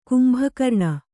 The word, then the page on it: ಕುಂಭಕರ್ಣ: kumbhakarṇa